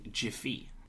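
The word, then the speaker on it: Giphy: The most famous way to use GIFs is using Jiffy, which is how they pronounce it.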